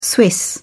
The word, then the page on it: swiss: British English